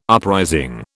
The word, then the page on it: uprising: British English